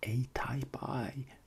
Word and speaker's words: ATypI: Och nu byter jag till mitt engelska uttal av atypi, det vill säga A-Type I.